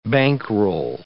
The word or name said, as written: bankroll